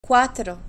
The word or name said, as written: cuatro